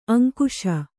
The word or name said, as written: ಅಂಕಿಸು